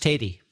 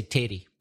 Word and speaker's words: tatie: T-A-T-I-E, tatty. – But pronounced teddy. – Okay, because tatty I've heard of as meaning potato. – Yes, in Scotland. – Right. – But in Northern Ireland, no, we'd say teddy.